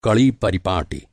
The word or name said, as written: परिपाटी